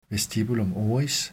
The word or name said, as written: vestibulum oris